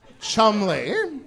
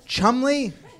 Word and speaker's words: Cholmondeley: I'm looking at this phrase and I see Marchioness of Cholmondeley. And you're saying what? – Chumley. – That's pronounced Chumley? – Yes.